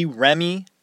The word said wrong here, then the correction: Ermey: I'm sorry. Did I say his name wrong? I did. All right. R. Lee Ermey.